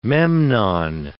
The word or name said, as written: Memnon